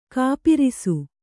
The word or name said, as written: ಗೋಪರಸ